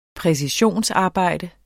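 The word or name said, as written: Præcisionsarbejde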